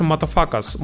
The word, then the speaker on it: Mutafukaz: Короче, мультфильм называется «Матафакас». «Матафакас», причем напишется через... очень странно, то есть она пишется M-U-T-A-F-U-K-A-Z.